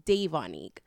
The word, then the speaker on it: Davonique: So my full name is Davonique, and they had a system where it was called demerit. So the teacher mispronounced my name and I was like, no, it's Davonique.